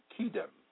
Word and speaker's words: kedem: The Hebrew word for eternal is kedem, pronounced kedem, meaning east, antiquity, ancient, front, that which is before, aforetime.